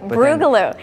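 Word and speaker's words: Brewgaloo: Gorgeous weather for Brugaloo as well. Am I saying that right? – Yep, Brugaloo. It's fun in downtown Raleigh.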